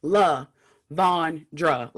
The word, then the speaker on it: LaVondra: You know, the first part is definitely, like, it's even spelled like La Von Dra.